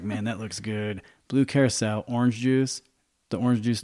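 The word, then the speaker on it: Curaçao: Uh, blue curacao.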